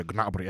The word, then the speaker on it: Gnabry: Uh, attenzione, dopo meno di un minuto Gnabry già va vicino al gol con una cavalcata. – Madre di Dios, la pronuncia è Gnabry, è tedesco, Gnabry, non è francese.